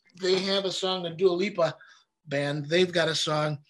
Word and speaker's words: Dua Lipa: Dua Lipa? – Thank you.